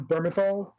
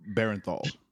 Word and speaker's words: Bernthal: And John Bernthal, how do you pronounce his name? – Bernthal.